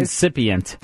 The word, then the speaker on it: incipient: Lisa, get rid of those incipient blackheads. – You have to say it right first. – Incipient. – There you go.